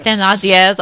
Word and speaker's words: Thénardier: Cosette is living with the Thénardiers, who I really can't just pronounce her name. – Thénardiers.